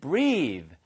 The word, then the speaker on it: breathe: 발음 하나만 주의하세요. 얘는요. breath가 아니고요. breath까지만 쓰면 숨이란 뜻입니다. breath, breath. 그렇죠? 예, 그런데 breathe 해서 e가 붙는 순간 소리가 바뀌는 거죠.